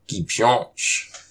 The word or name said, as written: Kipshôsh!